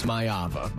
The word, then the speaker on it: Maiava: According to UNLV, where he used to play, it's Mayava, but who knows? – Yeah, Mayava.